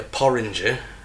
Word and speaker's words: porringer: It doesn't rhyme with orange, does it? A porringer. – Porringe. – You said a porringer? – Yeah, porringer, orange. It's one of the only things that does rhyme with orange. Porringer. – It's just orange with peas stuck in the front of it, though. – No, ER on the end. – Oranger is oranger than that rhyme. – That rhymes with oranger. Doesn't rhyme with orange, sorry.